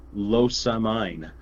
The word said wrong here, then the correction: Lusamine: No, it's Lusamine.